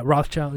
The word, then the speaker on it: Rothschild: Yeah, Rothschild, yeah.